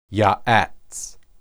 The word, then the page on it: yaats: Hebrew